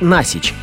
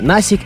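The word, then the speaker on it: Nasić: Именно потому, что у Сандры хорватские корни, правильно произносить ее фамилию как Насич, а не Насик или Насец.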